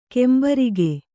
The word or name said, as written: ಕುಂಬಾರಿಕೆ